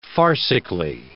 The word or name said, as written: farcically